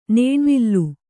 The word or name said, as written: ನೇಣ್ವಲೆ